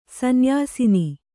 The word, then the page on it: ಸನ್ಯಸನ: sanyasana